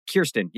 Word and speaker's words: Kirsten: Kirsten, yes, Kirsten Dunst.